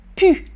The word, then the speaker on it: plus: Je prononce même très familièrement, quand on parle très familièrement avec la famille, avec les amis, on peut même prononcer pu.